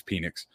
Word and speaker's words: Penix: And then from that team, Michael Penix, I know it is pronounced Penix, but I just can't. It's Penix.